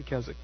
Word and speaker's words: Keswick: So the W is silent. I didn't know that until I read that. I was pronouncing it Keswick, but it's actually Keswick.